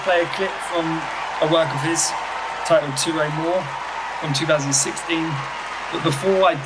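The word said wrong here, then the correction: Tyrau Mawr: So, I'm just going to correct your pronunciation. It's a Terrain Mawr, which means big towers,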